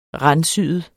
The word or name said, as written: randsyet